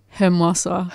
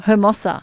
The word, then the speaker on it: Hermosa: Hermosa. – Hermosa.